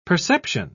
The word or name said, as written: perception